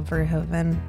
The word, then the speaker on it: Verhoeven: Despite awesome special effects, Hollow Man falls short of other films directed by Paul Verhoeven.